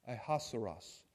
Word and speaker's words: Ahasuerus: And in the Hebrew, it is pronounced Achashverosh, and in English, Ahasuerus. So they say that it comes from the Hebrew, trying to say it in the Hebrew, and that's where it comes from.